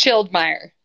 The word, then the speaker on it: Schildmeyer: So yes, tonight we're joined by Katie, and let me know if I say your last name wrong. Is it Schildmeier? – It's Schildmeier.